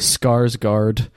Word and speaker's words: Skarsgård: How do you say it? – Skarsgård. – Oh yeah, Bill Skarsgård.